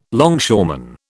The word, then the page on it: longshoreman: British English